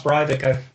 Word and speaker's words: Breivik: I'm pretty sure it's pronounced Breivik. I was wondering myself, and I went and searched for it and put a comment on my second to last blog about it, and a commenter there confirmed that that's their idea too in Norway as to how to pronounce it.